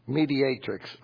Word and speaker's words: Mediatrix: She's not a holy matrix. Mediatrix. I knew I kept saying that wrong.